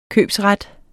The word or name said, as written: købsret